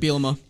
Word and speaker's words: Bielema: Bielema.